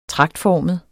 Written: tragtformet

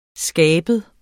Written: skabet

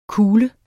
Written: kugle

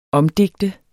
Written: omdigte

